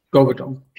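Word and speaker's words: Gvardiol: Is it Garvidal? – Garvidal. – Yeah.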